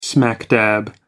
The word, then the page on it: smack dab: British English